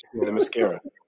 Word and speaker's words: Themyscira: Themyscira.